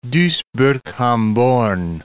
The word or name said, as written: Duisburg-Hamborn